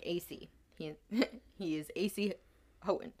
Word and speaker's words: Houghton: Houghton... – Oh, we read Houghton, but it's pronounced Houghton.